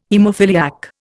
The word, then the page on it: haemophiliac: Indian